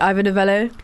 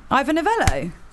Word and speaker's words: Ivor Novello: The Ivor Novello Awards. – Babe, that's exactly correct. Ivor Novello. – Yes. – Smashed it on the first go.